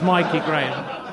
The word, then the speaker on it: Mikey Graham: I had to look his name up and I still mispronounced it. It's Mikey Graham.